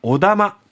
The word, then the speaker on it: お玉: 玉と書いてお玉と呼ばれる木製の玉を競り子が奪い合う。